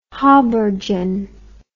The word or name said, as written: haubergeon